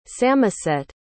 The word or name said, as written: samoset